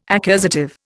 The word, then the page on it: accusative: Indian